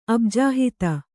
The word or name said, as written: ಅಬ್ಜಹಿತ